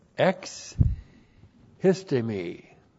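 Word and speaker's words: ex histemi (ἐξίστημι): That's E-K-S-I-S-T, long E-M-I, ex histemi.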